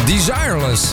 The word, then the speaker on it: Desireless: Desireless, hè? Ja, zo spreek je dat uit officieel.